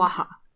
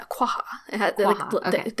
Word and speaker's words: quagga: And Quagga. – The Quagga. The Q is like barely there. Quagga. – Quagga. Okay. – And technically it's supposed to be the Quagga, like quagga, quagga, quagga.